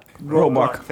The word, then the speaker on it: Roebuck: Roebuck.